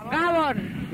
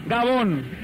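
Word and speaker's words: Gabon: Gabón. – Gabón.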